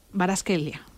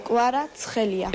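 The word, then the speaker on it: Kvaratskhelia: ¿Cómo se dice la estrella? Baraskelia. – Huicha Baraskelia. – Huicha Baraskelia. Repetimos, por favor. – Huicha Kuarazgelia. – Es que dice como Kuarazgelia.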